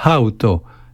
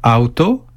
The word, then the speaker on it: hauto: Terminons sur un mot concernant l'ensemble de notre corps, la hauto, la peau. Attention à la prononciation, on doit bien entendre le souffle du H. En effet, auto, sans H, c'est une voiture.